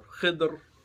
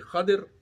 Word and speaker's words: Khidr: Now, another part of the background, who is Al-Khadr or Khidr, or different ways of pronouncing it, because it's Kha, Da, Ra, and there are no marks, so it can be Khidr, it can be Khadr.